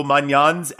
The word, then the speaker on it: Maignan: How much of an impact will Maignan's absence have on Milan? – I think the pronunciation was good.